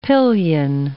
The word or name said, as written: pillion